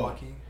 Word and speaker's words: Joaquin: Joaquin? – Oh. – Joaquin.